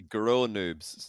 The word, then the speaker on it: Gross Noobs: I think it's actually Grow Noobs, because the S is silent.